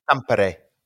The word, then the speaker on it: Tampere: Tampere. – Right. Because it's you didn't go Ampere. You put an accent on that.